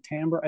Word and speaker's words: timbre: I think most people pronounce it timbre. I think it's a French word.